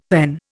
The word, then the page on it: PEN: Indian